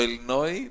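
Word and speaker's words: Illinois: Ότι το Ιλινόη δεν προφέρεται στο τέλο το S.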